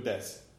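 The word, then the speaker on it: des (です): Right, des, and it's D-E-S-U, but you don't pronounce the U.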